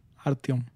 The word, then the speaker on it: Artyom: En este juego tomaremos control de Artyom. ¿Cómo lo pronuncias tú? – Artyom.